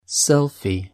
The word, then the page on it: selfie: British English